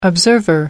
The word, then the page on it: observer: American English